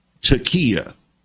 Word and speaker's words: taqiyya: In every situation, it's called taqiyya. It's spelled T-A-Q-I-Y-Y-A or I-Y-Y-A-H, taqiyya.